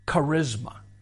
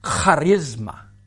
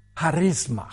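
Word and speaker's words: charisma (χάρισμα): Now, in the Greek, it is not pronounced charisma. It is pronounced in the way that the Jewish people often do with a hard CH. All right? It is pronounced charisma.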